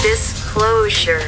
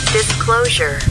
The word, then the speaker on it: Disclosure: Como é que fala essa palavra, por favor? – Disclosure. – Repita. – Disclosure.